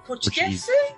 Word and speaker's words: Portuguese: And you're a very proud Portuguesean? Portuguese? How do you say that? – Portuguese. – Portuguese.